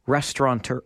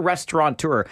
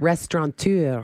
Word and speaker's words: restaurateur: But the word we use is restaurateur, but the N disappears for some reason. It's not a restaurateur. I'm not even sure how to pronounce it. Restaurateur. It's restaurateur. – But the N is not there. The N does not belong. There's no N.